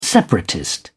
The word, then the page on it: separatist: British English